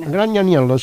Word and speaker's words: Gragnaniello: E i testi, il testo è di Enzo Gragnaniello, non so se lo pronuncio bene. – Gragnaniello, sì, è